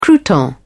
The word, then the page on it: crouton: British English